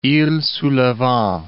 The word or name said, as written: iles sous le Vent